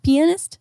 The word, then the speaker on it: pianist: Penist? Isn't that how you say it? Pianist? – No, it's pianist. Pianist. Yeah, say pianist.